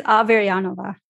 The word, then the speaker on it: Averyanova: Yeah, Elizabeth Averyanova.